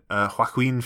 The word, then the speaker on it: Joaquin: Joaquin Phoenix was Johnny Cash. – Yes. – Yep. Jamie Foxx was Ray. Don't laugh at my pronunciation of Joaquin. That's how you meant to pronounce it.